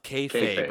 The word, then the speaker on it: kayfabe: It is pronounced kayfabe, right? It's kayfabe. – Yeah. – Right? K-fabe. Yeah, it's kayfabe. – K-Y-F-A-B-E.